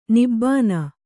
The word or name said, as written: ನಿಬ್ಬಾನ